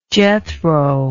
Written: Jethro

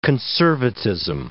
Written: conservatism

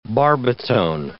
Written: barbitone

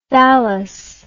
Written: thalass

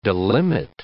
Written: delimit

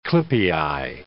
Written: clypei

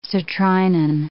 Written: citrinin

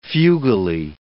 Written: fugally

